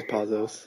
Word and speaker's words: Pazos: I think it's Pazos, right? – I think it is Pazos.